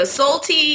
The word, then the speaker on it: Solty: So Salty is spelled S-O-L-T-Y. Ray is R-E-I, like Ray Ayanami from Neon Genesis.